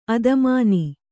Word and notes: ಆಧ್ಮಾನ: ādhmāna

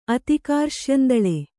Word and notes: ಅತಿಕಾರ್ಶ್ಯಂದಳೆ: Kannada

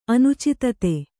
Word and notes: ಅನುಚಿತತೆ: anucitate

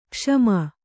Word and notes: ಕ್ಷಮ: kṣama

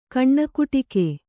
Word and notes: ಕಣ್ಣಕುಟಿಕೆ: kannada

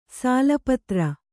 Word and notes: ಸಾಲಪತ್ರ: sāla patra

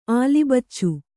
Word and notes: ಆಲಿಬಚ್ಚು: kannada

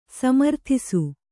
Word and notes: ಸಮರ್ಥಿಸು: samarthisu

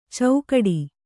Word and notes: ಚೌಕಡಿ: caukaḍi

